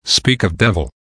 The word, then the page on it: speak of devil: American English